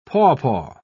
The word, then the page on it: papaw: British English